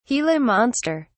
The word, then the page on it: gila monster: British English